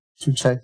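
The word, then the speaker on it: chuchaqui: Chuchaki, yeah. – Gotcha. I just... – Chuchaki, it's... Chuchaki is an Ecuadorian word.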